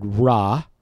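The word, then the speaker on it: ra': The word is the word ra, as in ra, ra, ra. It's spelled R-A apostrophe.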